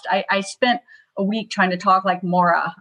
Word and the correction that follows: Moira: I'm probably mispronouncing her name, but I Moira. Love that show. Moira.